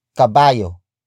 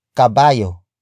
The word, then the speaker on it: kabayo: It is the focus marker that introduces the main noun of the sentence, and that is kabayo, kabayo, kabayo.